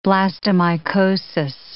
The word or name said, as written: blastomycosis